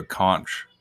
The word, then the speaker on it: conch: Sounds like someone's blowing into a conch shell or whatever the whole time. Is that how you say that? – Conch. – I never know. – Conch. – Conch, yeah, conch. Why it's spelled conch, though, right? – Yeah.